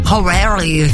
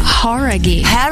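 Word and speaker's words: Jauregui: Horaghi, não é? – Horaghi. – Horaghi. – Então a gente tava falando errado, cara.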